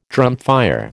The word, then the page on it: drumfire: American English